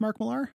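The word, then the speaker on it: Mark Millar: I have a complicated relationship with Mark Miller, mm-hmm. – is actually, I think, how you're supposed to pronounce it.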